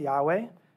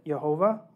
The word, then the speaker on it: Yahweh: So it's traditionally pronounced Yahweh, or some pronounce it Jehovah or Jehovah, which adds the vowels from my Lord, or Adonai, which is the term that the other prophets used.